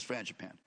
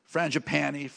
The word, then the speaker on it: Frangipane: Same friend, Francis, says, you know, he's Italian, Francis Frangipane. It's funny because it's an easy name for me to pronounce, but we go in a restaurant and people would, Frangipani, Frangipang, Orangutan.